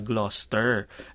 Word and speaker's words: Gloucester: Ito din yung pag binasa mo Gloucester, yung pala Gloucester.